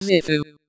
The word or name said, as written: doctor